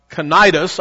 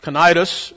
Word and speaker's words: Cnidus: And when we had sailed slowly many days and arrived with difficulty off uh, uh, Cnidus, I looked up the pronunciation of that because I wasn't sure how you pronounce a word that starts with C. Uh, but Cnidus, the wind not permitting us to proceed, we sailed under the shelter of Crete off Salmon.